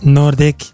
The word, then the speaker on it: Nordic: Nordic Black Theater, lo pronuncio en noruego, es Nordic. Nordic Black Theater.